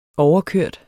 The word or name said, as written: overkørt